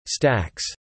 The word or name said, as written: stacks